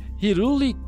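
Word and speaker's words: Hyrule: Começou com o nome de Hiruli Castletown. É essa pronúncia, né, Rafa? – Ah, tá certo.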